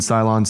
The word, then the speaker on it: Ceylon: I went with a little MCT oil and the C8, little cinnamon, Ceylon cinnamon.